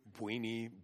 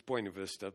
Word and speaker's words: Buena Vista: They pronounce it some horrible Texas pronunciation up there. Buny, that's how they say it. Anyway, it's Buena Vista.